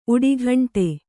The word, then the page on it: ಉಡಿಘಂಟೆ: uḍighaṇṭe